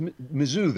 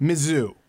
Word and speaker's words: Mizzou: How do I pronounce it, Andrew Klavan? – It's Mizzou, they call it. – Mizzou.